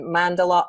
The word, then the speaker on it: mandala: Has been teaching workshops throughout her career, has a special interest and passion in mandala art, interpretation, and journaling.